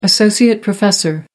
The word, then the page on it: associate professor: American English